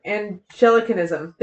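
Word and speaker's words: Anglicanism: Angelic. And I'm putting the emphasis on the wrong syllable is what's happening. That's actually what's happening. Hold, please. Angelicanism. There we go.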